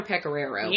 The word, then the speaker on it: Pecoraro: Priest, that was actually involved, Father Picario, I'm probably going to butcher this guy's name. I'll have to find it exactly so I don't butcher his name, guys. But he mentioned there has been speculation. Let me see. Father Pecoraro. Sorry, guys. Father Pecoraro. – You try to pronounce that like that. – Yeah, Pecoraro. Hooked on phonics.